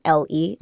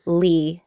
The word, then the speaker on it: Le: So the traditional pronunciation of the last name L-E is Le, but I'm used to calling myself Lee, L-E,